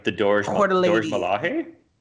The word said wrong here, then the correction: Dora Milaje: Sorry. I don't know if I pronounced that right. Dora or Dorj Malahe? – I can never pronounce anything. – I'm embarrassed. – Dora Milaje. – I think it's Dora Milaje.